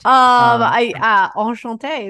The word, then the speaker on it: Enchanté: The Rose, the Palo Steakhouse, and Enchanté by Chef Honor Lelemon. So that will all be there as well. Did I say that right? I don't know. Did I get it right? – Enchanté is correct.